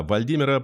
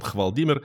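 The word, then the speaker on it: Hvaldimir: Зоозащитники заявили, что на теле умершего кита Вальдимира, подозреваемого в шпионаже в пользу России, нашли пулевые ранения. В прошлый раз я неправильно сказал, что он Хвалдимир, потому что пишется Хвал, а читается по-норвежски Вал.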